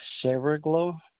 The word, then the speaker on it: Saraglow: Stephen Saraglow.